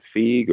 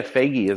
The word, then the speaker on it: Feige: Is it Feige or Feige? How do you pronounce it? – Feige is what I've heard.